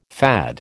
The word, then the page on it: FAD: American English